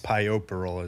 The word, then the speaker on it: puerperal: P U E R P E R A L. Puerperal, is how I read it, but it's nice. – pioperal is how you pronounce it.